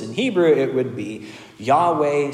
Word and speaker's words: Yahweh: but as near as we can tell, is pronounced Yahweh.